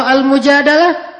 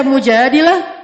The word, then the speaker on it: Al-Mujadalah: Kalau Al-Mujadalah, curhat. Jadi tergantung kita bacanya dilah atau dalah. Kalau kita baca Mujadilah, berarti perempuan yang lagi curhat. Kalau kita baca Al-Mujadalah, berarti curhat itu sendiri.